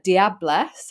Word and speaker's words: Diablesse: To our lovely guest, who is Cleo Farman from Diablesse.